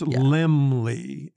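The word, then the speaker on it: Laemmle: It's a weird spelling. Limley? – Yeah, it's Limley. L-E-H-M-L-E-Y.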